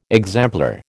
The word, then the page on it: exemplar: American English